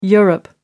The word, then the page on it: europe: American English